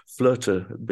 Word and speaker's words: flöte: Rök, you know, old Icelandic word meaning destiny, and flöte being the German pronunciation and spelling of the instrument I play.